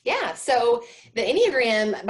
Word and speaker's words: Enneagram: Engram, right? The Engram, how do you pronounce that, right? – Enneagram. – Yeah, Enneagram. Okay, right? Like I just can never get the syllables right.